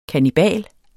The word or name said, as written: KANNIBALEN